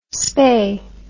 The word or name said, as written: Spey